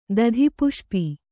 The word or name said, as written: ದಧಿಪುಷ್ಪಿ